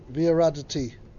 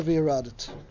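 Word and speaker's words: v'yaradit (וירדת): The pasuk said v'yaradit, and you should descend. But that's the way we pronounce it. There's a Korean exiv. The spelling is with a yud at the end. Viaradati. But we don't pronounce a viaradati, even though that's the way it's spelled. We pronounce a viaradit.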